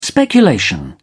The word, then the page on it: speculation: British English